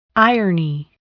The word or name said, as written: irony